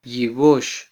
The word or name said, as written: yibox